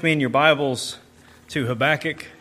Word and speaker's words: Habakkuk: We are looking at the first chapter of Habakkuk, right after Nahum and right before Zephaniah. I pronounce it Habakkuk. Some people have said Habakkuk, but we're going to go with Habakkuk.